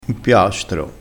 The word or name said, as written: impiastro